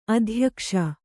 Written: ಅತ್ಯಾಕಾಶ

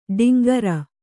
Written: ಟೇಂಕಾರ

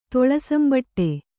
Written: ತೊಳಸಂಬಟ್ಟೆ

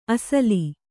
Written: ಅಸಲು

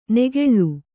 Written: ನೆಗೆಳ್ಳು

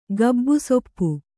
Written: ಗಬ್ಬುಸೊಪ್ಪು

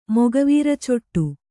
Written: ಮೊಗವೀರಚೊಟ್ಟು

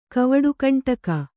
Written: ಕವಡುಗಂಟಕ

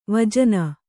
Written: ವಿಜನ

